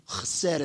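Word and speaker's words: chesed (חסד): Three letters in the Hebrew alphabet, chesed. Chesed is the way it would sound like if we're hearing a Jew pronounce it, chesed.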